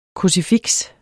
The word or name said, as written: krucifiks